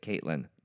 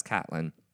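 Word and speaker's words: Katlyn: But her name tag said K-A-T-L-Y-N. I don't know. Just plain old white girl from Kansas, and she pronounces it Caitlin. No, no, you don't, because it's K-A-T-L-Y-N. That's Catelyn. If you wanted Caitlin, it's K-A-I-T or K-A-T-E, whatever.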